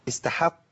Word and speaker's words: استحق: طيب استحى استحق، طبعا استحق ايه؟ استحق واستحق عليهم الاثنين. اخد بالي من ايه؟ من ترقيق الحاء وعدم تفخيمها.